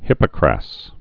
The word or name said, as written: hippocras